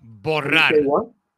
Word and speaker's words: borrar: How do you say delete in Spanish? – Borrar. Borrar. Borrar. You got to roll that R, though.